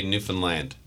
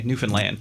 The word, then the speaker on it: Newfoundland: Yeah, Newfoundlanders would just say Newfoundland. – Okay, Newfoundland. – Yeah, the emphasis is on the land.